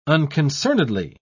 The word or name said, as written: unconcernedly